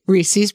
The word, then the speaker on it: Reese's: Reese's peanut butter cups. – It's Reese's. Like, it rhymes with pieces.